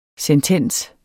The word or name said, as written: sentens